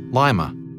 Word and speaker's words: Lima: The city, by the way, gets its name from Lima, the capital of Peru, but locals insisted on pronouncing it Lima.